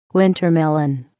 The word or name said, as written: winter melon